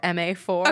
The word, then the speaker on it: MA04: MA04 or MA4? – Okay, so it's hashtag MA04, but the zero is silent when you say it.